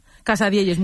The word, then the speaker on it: Casadielles: ¿Lo digo bien? – Casadielles, muy bien dicho.